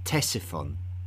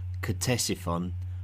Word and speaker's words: Ctesiphon: Its name is Ctesiphon, although you may hear it also called Ctesiphon by those who pronounce the otherwise silent C at the beginning of its name.